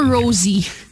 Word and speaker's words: Rosé: Rosé.